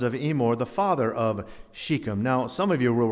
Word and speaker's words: Shechem: And I want you to notice two surprising references to an Old Testament valley called Shechem. Chapter 7, at least that's the correct pronunciation,